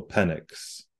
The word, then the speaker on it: Penix: His name is pronounced Michael Penix, like Penix.